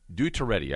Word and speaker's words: Duterte: His name is Mr. Duterte. Duterte, I think that's probably the best way I can pronounce it. D-U-T-E-R-T-E, Duterte.